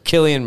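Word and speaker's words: Cillian: Killian Murphy.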